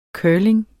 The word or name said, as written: curling